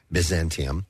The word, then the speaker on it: Byzantium: They're from Byzantium Empire. I hope I'm saying that correctly. Byzantium. – Yes.